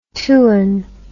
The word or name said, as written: tuan